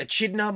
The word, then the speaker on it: Echidna: Echidna monster.